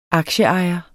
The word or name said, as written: aktieejer